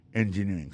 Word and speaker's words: engineering: Electrical engineering. C'est sur deuxième syllabe là. Elect là, plus appuyé. Electrical engineering. Engineering, ça, l'accent tombé le même sur avant dernier syllabe là.